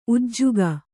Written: ಉಜ್ಜುಗ

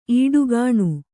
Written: ಈಡುಗಾಣು

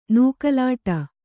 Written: ನೂಕಲಾಟ

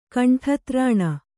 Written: ಕಂಠತ್ರಾಣ

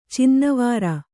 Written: ಚಿನ್ನವಾರ

